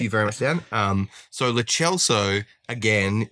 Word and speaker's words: Lo Celso: The other thing is Lo Celso is how you say it.